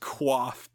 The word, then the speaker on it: coif: I believe the word C-O-I-F is pronounced quaff, but a discussion for another day, perhaps.